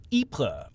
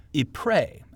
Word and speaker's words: Ypres: and third battles of Ypres, or Ypres, as Americans might pronounce it.